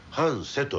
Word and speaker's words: 繁盛: はあ、それで移り住んだのが一宮町の半瀬地区。これ、はい、繁盛と書いて半瀬と読むんですね。